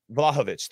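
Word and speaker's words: Vlahović: I think so. Vlahovic. I was going to put the emphasis on itch, right? Vlahovic. – Yeah, Vlahovic.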